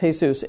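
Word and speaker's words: Jesús: It looks like Jesus. We have a lot of Mexican people who are named Jesus, but it's pronounced Jesus.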